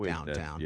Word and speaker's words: Downton: I know it's pronounced Downton, but we call it Downtown.